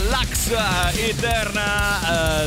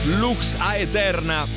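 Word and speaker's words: Lux Aeterna: Come dicono i Metallica, l'ax eh, eterna eh, su Radio Rock. Eh, insomma, quando invece, se vogliamo pronunciarla bene, si dovrebbe dire Lux a eterna.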